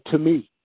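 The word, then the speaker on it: to me (τιμή): In the Greek, it's spelled T-I-M-E, it's spelled time. It's not pronounced that, but it's spelled T-I-M-E. It's called to me.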